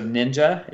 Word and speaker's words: NYNJA: And then last year I met the founders of Ninja. It's spelled NYNJA.